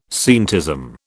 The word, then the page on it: scientism: British English